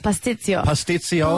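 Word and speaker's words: pastitsio: Gone into there and not, he's just hacked away at the patizio. Is that how you say it? – Pastizio.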